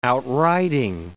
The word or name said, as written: outriding